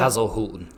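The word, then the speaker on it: Hasenhüttl: How do you say his name there? – Hazelhutten. – Hazelhutten.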